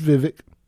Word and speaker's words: Vivek: It's Vivek.